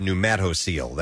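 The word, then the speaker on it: pneumatocele: By the way, somebody said it's pronounced pneumatocoel.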